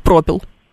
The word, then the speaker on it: пропил: Ну, каламбур правильно, конечно, и правильно будет пропил. – А почему пропил? – Ну, так вот смещается ударение.